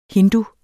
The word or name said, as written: hindu